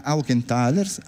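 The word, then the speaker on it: Aukenthaler: Poi ci spostiamo con Anna Aukenthalers. Anna, ho detto bene? – Benissimo.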